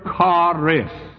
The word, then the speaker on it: charis: In the Greek, it's the word charis. We would spell it C-H-A-R-I-S. Charis.